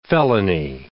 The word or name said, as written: felony